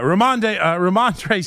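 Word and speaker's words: Rhamondre: This week's football hot performer, sponsored by Edison HVAC, is Romando. I always forget. I always say his name terrible. Yeah, Ramondre Stevenson.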